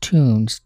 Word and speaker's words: TUNS: That's a name that could be pronounced Tunes, T-U-N-S, inspired by the Technical University of Nova Scotia, where the three band members all either attended or played basketball.